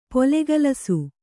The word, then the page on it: ಪೊಲೆಗಲಸು: polegalasu